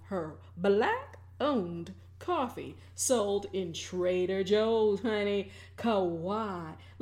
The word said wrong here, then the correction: Kahawa: Let me get the name right. It is Kahawa 1893.